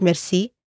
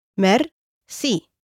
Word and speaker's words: merci: Another simple and common way of saying thanks is the French word merci, pronounced in an Egyptian way. Let's break it down. Merci. Once more. Merci.